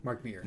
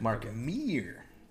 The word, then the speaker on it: Mark Meer: Mark Mears? – I'll give it to you. No S. – Mark Meer. – Mark Meer.